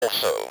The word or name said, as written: also